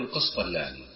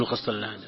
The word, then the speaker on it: القسطلاني: الحظ أخواني دكتور، هذه يعني على الجانب نأخذه عن دكتور لطيفة، أنكم دائما تقولون القسطلاني في دروسكم، هو اسمه هكذا؟ – نعم القسطلاني، القسطلاني نعم نعم. – لكن الناس ينطقون القسطلان. – لا لا، بالتشديد بشديد اللام.